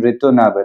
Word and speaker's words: ritonavir: That is uh, lopinavir and ritonavir.